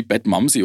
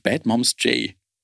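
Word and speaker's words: Badmómzjay: der hat es nämlich wieder gewonnen und ich habe ihren Namen vollkommen falsch ausgesprochen. I did not know. Ich habe die, glaube ich, irgendwie Bad Momsy oder irgendwie äh, genannt und sie heißt eigentlich Bad Moms J.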